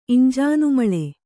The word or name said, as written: ಇಂಜಾನುಮಳೆ